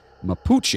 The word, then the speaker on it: Mapuche: In the very southern part of South America, there lived a group of people known as the Mapuche.